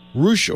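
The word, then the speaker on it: Ruch: How do you say that, Ruck, Oregon, is that right? – Roush, Oregon.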